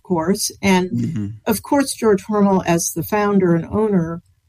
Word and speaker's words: Hormel: And we need to say it right. Is it it's Hormel. – It's Hormel. Hormel. Okay, yes. Hormel. – Yes. Uh-huh. Although most of us know it as Hormel. – But it's Hormel. – As I understand from everybody in Austin, the family pronounces it Hormel.